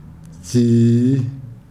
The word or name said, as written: jira